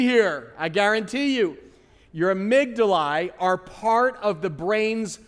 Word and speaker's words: amygdalae: Lord, I thank you for my amygdalae. Let's pronounce that funny word again. Amygdalae.